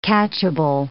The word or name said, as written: catchable